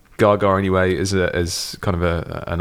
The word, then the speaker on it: Gaga: I find it's pronounced Gaga. – Gaga.